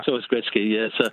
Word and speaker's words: Gretzky: Wayne Gretzky has always been pronounced Wayne Gretzky, and I suspect Michael just wasn't familiar with how to pronounce it when he read it off off.